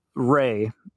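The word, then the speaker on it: Ra: This entity claimed to be, it went by the name, Ray is how it'd be pronounced, but it's R-A, and said that it had communicated with humans in the past, in ancient Egypt, and it presented itself as this god, Ray.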